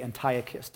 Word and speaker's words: Antiochus: Antiochus Epiphanes IV. Antiochus Epiphanes. You might say Antiochus, depending on your pronunciation of him.